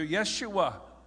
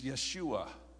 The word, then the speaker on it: Yeshua: Yeshua. Okay, you'll discover when you go to Israel that J's may be seen. You may see the letter J, but they're never pronounced. Jesus is Yeshua.